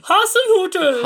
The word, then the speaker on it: Hasenhüttl: Hasenhüttl's been... I always feel like I want to pronounce it, oh, Hasenhüttl. – Hasenhüttl!